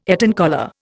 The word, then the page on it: Eton collar: Indian